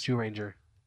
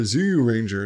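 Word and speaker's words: Zyu Ranger: Jew. It sounds like Jew, yes. Jew, J-E-W. It's spelled Z-Y-U because that's how it's pronounced in Japanese. – Jew Ranger.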